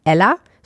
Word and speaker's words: Ela: Und auf einmal, Anfang dieses Jahres, poppte er dann wieder auf und hat jetzt ein neues Restaurant, das heißt Ella, schreibt sich E-L-A, das ist die türkische Variante von dem Namen, deswegen spricht man ihn so aus, als hätte er 2 L, gewissermaßen.